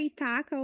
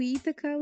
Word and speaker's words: Ithaca: É, pode pronunciar Itaca ou Ithaca, os dois são corretos.